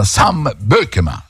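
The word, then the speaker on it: Beukema: Rimarrà con 4 centrali, rimarrà con Rachmani, rimarrà con Rafa Marin, con Marianucci, con Bökema, Sam Bökema, così si pronuncia, l'ha detto anche lui ieri sera, Sam, non Sam, Sam Bökema, questa è la vera pronuncia.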